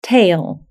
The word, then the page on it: tail: British English